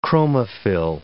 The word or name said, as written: chromophil